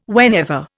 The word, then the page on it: whenever: Indian